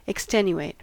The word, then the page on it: extenuate: American English